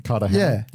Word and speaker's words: Cartagena: So is it Hena? Cartagena? – Yeah. – Yeah, I pronounced the G as a G.